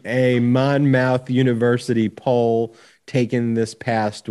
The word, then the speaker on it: Monmouth: It's pronounced Monmouth. It's Monmouth College.